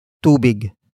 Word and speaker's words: tubig: And that is tubig. Tubig. Tubig. Tubig is spelled as T. U-B-I-G.